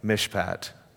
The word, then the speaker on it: mishpat: Uh, justice in the Hebrew is the word, and I'm going to mispronounce it, but as a Canadian giving Texans a Hebrew word, it sounds like mishpat.